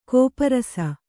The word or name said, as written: ಗೋಪರಸ